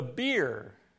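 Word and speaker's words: bier: The beer. The beer. It's pronounced just like their beverage.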